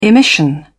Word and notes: emission: British English

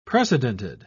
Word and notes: precedented: British English

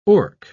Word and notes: orc: British English

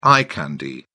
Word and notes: eye candy: British English

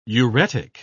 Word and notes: uretic: British English

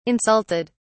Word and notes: insulted: British English

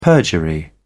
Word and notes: perjury: British English

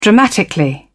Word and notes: dramatically: British English

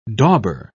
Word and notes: dauber: British English

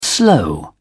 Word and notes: slow: British English

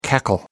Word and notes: cackle: American English

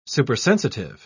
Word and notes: supersensitive: British English

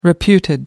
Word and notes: reputed: American English

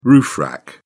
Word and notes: roof rack: British English

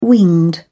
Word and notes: winged: British English

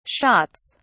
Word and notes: shots: British English

